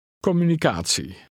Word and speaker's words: communicatie: The ending T-I-E is pronounced like the word C, preceded by a soft T, C. Try to read 10. – Communicatie.